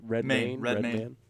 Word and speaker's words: Redmayne: I think that's how you say it, Redmayne.